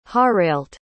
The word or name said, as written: harailt